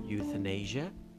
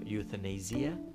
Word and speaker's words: euthanasia: پس یوتنیژه یا یوتنیزیه انگلیسی هستن و اوتانازی که ما میگیم اوتانازی اگر من درست تلفظ بکنم بر صورت به فرانسه اون چیزی هستش که معمولا شایع هستش و پزشکا و افراد ازش سخن به